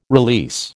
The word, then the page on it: release: American English